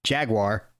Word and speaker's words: Jaguar: There's also, that's the way the British pronounce what we in America say is Jaguar.